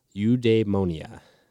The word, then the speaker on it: eudaimonia: So this fulfillment and happiness and immediate payoff and like this drive and everything led me to a word, and it's Greek. And let's see if I can pronounce it right. It is Greek, and it is called eudaimonia. That's it. Yep.